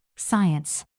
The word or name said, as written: science